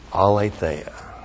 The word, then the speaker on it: aletheia: And then, aletheia. A-L, long E-T-H-E-I-A, aletheia. And aletheia is the Greek word for absolute truth.